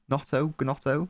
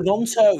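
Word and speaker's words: Gnonto: Is it Gnotto? Nonto. – Oh, Nonto.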